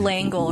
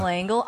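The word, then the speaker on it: L'Engle: Madeline Lang. I can never say her name last name. – Langle, right? – Madeline Langle.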